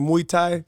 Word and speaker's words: Muay Thai: Next time I randomly check in on the gram, I see you do, I can't even pronounce, like Muay Thai. – That's it. You said it right.